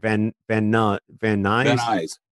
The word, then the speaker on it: Van Nuys: Van Nuys.